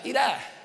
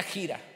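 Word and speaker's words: Hira: En realidad se pronuncia gira, pero estamos en México y se ve mejor ira.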